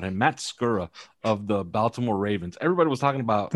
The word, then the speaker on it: Baltimore: I don't even think they pronounce the T. I think it's just Baltimore.